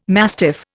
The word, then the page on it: mastiff: Indian